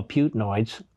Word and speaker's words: Putinoids: But that aside, the propagandists for Putin, who I call Putinoids because it rhymes with hemorrhoids,